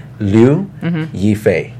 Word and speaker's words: Liu Yifei: Her name is pronounced Liu Yifei.